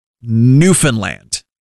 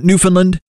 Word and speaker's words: Newfoundland: But a quick PSA to anybody across the world who is discovering Newfoundland and Labrador for the very first time. It's pronounced Newfoundland, not Newfoundland. Not Newfoundland. It's a combination of the two. It rhymes with understand.